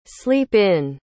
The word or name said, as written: sleep-in